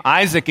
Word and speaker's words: Isaac: And if you don't know, the name Isaac in Hebrew is the name laughter. So they named him laughter, right? We hear Isaac, that's the Hebrew pronunciation of this word, but it means laughter.